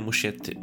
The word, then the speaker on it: Muschetti: ale reżyser Andy Maschetti się chyba wymawia, co? Czy Maschetti? Ja słyszałem Muschetti. muschetti.